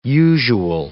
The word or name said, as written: usual